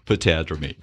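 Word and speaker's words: potamodromy: Pedromony. Is that how you say it? – Patadromy.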